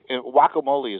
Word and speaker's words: guacamole: It's like guacamole is actually pronounced, the G is pronounced as a W.